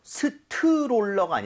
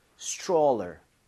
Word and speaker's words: stroller: 그래서 스트롤러가 아니라 입 모양을 좀 모으면서 stroller 이렇게 해서 발음을 해보는 거예요. 두번 갑니다. stroller, stroller.